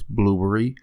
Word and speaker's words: Blubrry: And I did not say it wrong. It's not Blueberry, it's Blueberry. It's BLUBRRY.